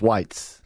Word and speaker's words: Weitz: It is Chaim Weitz, or Weitz, depending on if you pronounce the E-I-Y or we. – You pronounce the long sound of the second letter. So it's, how do you spell it? – W-E-I-T-Z. – Then it's Weitz. – Okay, Weitz.